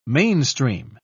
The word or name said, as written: mainstream